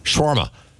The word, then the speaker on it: shawarma: Shawarma Day.